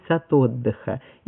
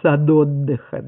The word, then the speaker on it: сад: Она называлась «Сад отдыха». И фиксировали, сколько водителей произносили неправильно «сад отдыха», да? А сколько правильно «сад отдыха».